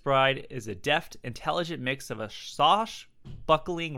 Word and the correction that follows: swashbuckling: romance and did I say that right? Sosh buckling? – I think it's swash buckling.